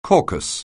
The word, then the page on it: caucus: anglais